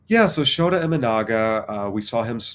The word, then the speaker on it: Imanaga: Okay, I'll probably get this name wrong. Shota Imanaga. – Yeah. Imanaga.